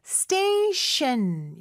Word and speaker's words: station: 자, station 할 때는요. 앞에 T 발음은 쌍디귿 발음으로 된소리로 해주시고요. 뒤에 T 발음은 SH 발음이죠. 그래서 station 이렇게 발음하시면 돼요.